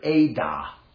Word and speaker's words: edah: And it is the word Ada. And you would spell it in English, E-D-A-H.